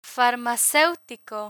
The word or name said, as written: farmaceutico